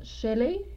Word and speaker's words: Shelley: Shelley.